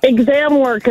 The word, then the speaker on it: ExamWorks: You work for, is it ZamWorks? – ExamWorks. It's Workman's Comp. We do Workman's Comp through California and Hawaii. – Zam, Zaddy. – Okay, how do we spell it? – How did Kevin spell it? – Yeah, how did Kevin spell it? – Kevin spelled it ZAM works. – God, Kevin. – Is that what it is? – No, she said exam works. – Oh, exam works.